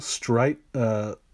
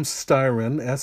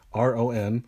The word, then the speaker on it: Styron: And this is a book called Darkness Visible, A Memoir of Madness by William Styron. Said that wrong. By William Styron, Styron.